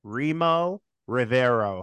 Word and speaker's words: Remo Rivero: Debuting on the show, I've got actor, writer, and director Remo Rivero.